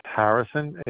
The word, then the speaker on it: Harrison: My first name is spelled A-R-I. Last name is Harrison, H-A-R-R-I-S-O-N.